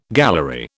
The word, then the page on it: gallery: British English